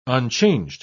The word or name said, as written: unchanged